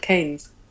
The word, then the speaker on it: Keynes: It's named after the poet John Milton and the economist John Maynard Keynes. – Wow. – But it's pronounced Keynes. Go figure.